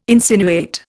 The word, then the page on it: insinuate: Indian